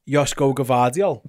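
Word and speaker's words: Joško Gvardiol: Can you pronounce like every letter in his name for me? – Josko Gvardiol. – Perfect. – Josko? – Yeah. – Gvardiol. – I think it's right.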